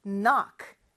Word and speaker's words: knock: 그래서 kn으로 시작하는데, 이렇게 kn으로 시작하는 단어들은 k 발음을 해주지 않는다고 했어요. 자, 너, 어 발음이니까 오가 오겠고, knock. 끝에는 ck로 끝나죠. 그래서 knock.